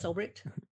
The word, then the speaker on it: Ulbricht: Wow, so yeah, Ross Ulbricht? Did I say that right? – Yep, yeah. Ross Ulbricht.